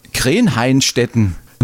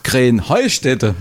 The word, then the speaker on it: Krähenheustätte: Ja, ihr saget immer Krähenhainstetten. Und dabei heißt es doch Krähenheustätte, oder wie? – Krähenheustätte. – Ja, genau, und das die Betonung auf dem Heu.